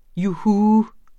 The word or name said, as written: juhu